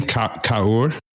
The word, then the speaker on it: Kaur: This is came to us through Valerie Kaur's work. So yeah, please go ahead, Dave. – Yeah. How do you say her name? Kaur? – No, it's like Kaur. Kaur. – Okay. – K-A-U-R.